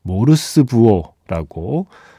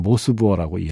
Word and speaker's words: 모스 부호: 모르스 부호라고 어, 발음하던 세대인 거죠. 이제는 모스 부호라고 이야기합니다.